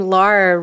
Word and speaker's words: Lara: I always say my name Lara rhymes with star, so yeah.